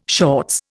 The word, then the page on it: shorts: Indian